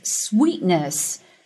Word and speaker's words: sweetness: Is its sweetness, 是它的这个甜度啊。这里的 sweetness, 我要说一下这个发音。这里的 ee 是长元音 e, 所以口腔有一个向左右拉伸。 Sweetness.